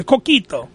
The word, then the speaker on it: coquito: Am I saying it wrong? Say the coquito. I want to make sure I'm saying it right. The coquito. – Yeah. – Okay. So, yes, you're correct.